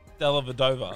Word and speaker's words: Dellavedova: That's why I said Dellavedova. – Respect. – There we go. Said it right.